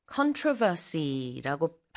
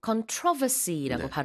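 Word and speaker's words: controversy: 이 논란, controversy라고 발음하기도 하고, controversy라고 발음하기도 합니다. 강세가 다른데요. 둘다 가능하고, 논란이 많은 국민투표였어요.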